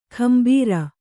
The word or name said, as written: ಗಂಭೀರ